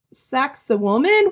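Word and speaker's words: Sacsayhuamán: All right, so the number one is called Sexy Woman, which I saw a few websites that were like, just say sexy woman. That's how you pronounce this.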